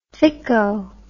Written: thicko

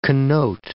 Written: connote

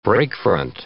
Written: breakfront

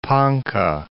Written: Ponca City

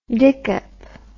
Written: dikkop

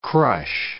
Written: crush

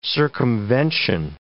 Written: circumvention

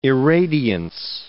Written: irradiance

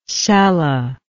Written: cella

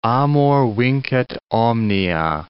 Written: amor vincit omnia

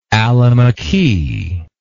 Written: alamiqui

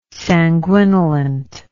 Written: sanguinolent

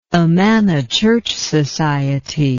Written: Amana Church Society

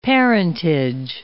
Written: parentage

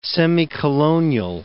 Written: semicolonial

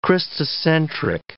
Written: Christocentric

